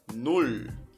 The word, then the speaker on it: null: Null. Note que para você pronunciar o zero, você vai falar nulo e só tira o O, vai ficar null, null, vai travar no L.